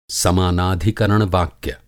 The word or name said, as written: समानाधिकरण-वाक्य